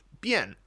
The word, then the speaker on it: 便: And yes, 便宜 is often pronounced bian, and bian means convenient,